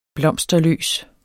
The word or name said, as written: blomsterløs